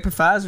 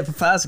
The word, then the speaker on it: Pfizer: Yeah, Pfizer, because the P's there. I'm sorry, Pfizer. But the P's there. If you're going to put the P there, I'm going to pronounce it.